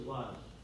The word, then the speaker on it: Luz: Now, that's how you pronounce it in Hebrew. The name of the city was Luz. But you know what? It looks like Luz to me. Doesn't it look like Luz to you?